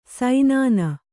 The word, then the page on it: ಸೈನಾನ: saināna